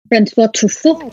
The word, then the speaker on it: François Truffaut: Can you give me the French pronunciation? – François Truffaut.